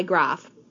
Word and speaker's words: Graf: My last name is spelled G-R-A-F. It's interesting because my husband and his brother pronounce that same last name differently. My husband pronounces it Graff. His brother pronounces it Graff. I mostly go by Graff, but I answer to both.